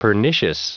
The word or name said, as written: pernicious